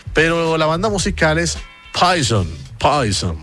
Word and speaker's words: Poison: y la ciútica de la vendedora lo pronunciaba Poisson. Poisson, no Poison.